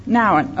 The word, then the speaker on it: Nouwen: The writings of Henry Nouwen, is that how you say it? I've only ever seen it. You know, he's a Catholic. How do you say it? – Nouwen. – Okay, thank you.